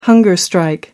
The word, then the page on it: hunger strike: American English